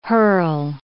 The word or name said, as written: hurl